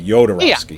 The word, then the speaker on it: Jodorowsky: Well, and I've always heard it, and they say it in the documentary, so, but it's the J sounds like a Y, Jodorowsky.